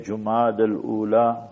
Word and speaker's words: جمادى الاولى: کبھی کبھی ہم دیکھتے ہیں تقویم میں کیلنڈر میں غلط جو ہے طور پر لکھتے بھی ہے تلفظ بھی, تو صحیح ہے جمادى الاولى جمادى الاخرى, تو یہ صحیح تلفظ بھی ضروری ہے.